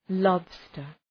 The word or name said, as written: lobster